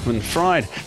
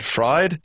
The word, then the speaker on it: Fried: And how did I say it? Fried? Fried, Fried? – as in eggs, yes.